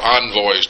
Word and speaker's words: envoys: Envoys. Or envoys, depending on which way you want to pronounce that word.